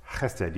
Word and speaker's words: chesed: Chesed. You got to have a little phlegm in your throat to say the beginning syllable.